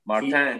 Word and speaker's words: Martin: Our friend Martin, I can't do the French pronunciation of that, so I'm not going to try. – Martin. – There you go.